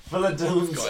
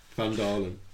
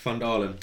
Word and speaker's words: Phandalin: Philodan's... how do you pronounce that? – Phandalin.